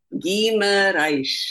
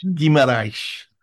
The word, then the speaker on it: Guimarães: Guimarães. – Guimarães.